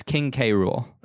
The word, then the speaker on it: K. Rool: Or is it Cruel just spelled with a K? – No, it's King K. Rool.